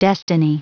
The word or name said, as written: destiny